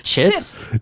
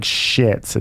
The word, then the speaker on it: chits: Chits? – Chits. – It's like chips with a T. Yeah, chits. Chips. There you go. Chits. – Not like shits. It's like chits.